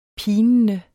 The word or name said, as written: pinende